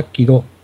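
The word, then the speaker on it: Kidō: Es Hanshichi, es Hanshichi, y el autor es, como suena, con K, Okamoto Kido, Kido, la O, ya sabes, con el sombrerillo que hace que sea una O alargada, entonces realmente sea Kido, un poquito más larga la O, yo no lo pronuncio muy bien, pero así es muy fácil.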